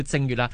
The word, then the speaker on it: 正月: 来以前咧，即系记得啦，秦始皇系嬴政啊嘛，咁佢就嗰阵时咧，大家都系不嬲都叫开正月正月，咁因为嬴政又觉得喂，好似同佢个名啊名有少少忌讳同音字啊，咁所以啲人咧就改咗名啦，就唔叫正月啦，就读正月，咁啊长征个征咁解。